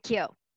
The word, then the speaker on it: Kyo: And when people don't know how to say it, I'm like Tokyo. – But without the toe. – Just with the Kyo. – Kyo.